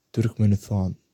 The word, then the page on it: Turkmenistan: turkmen